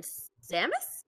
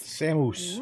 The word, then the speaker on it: Samus: James's team, we have Hawkeye, Katniss Everdeen, Master Chief, and Samus? – Yes. – Did I say that right? – Yes. – Samus.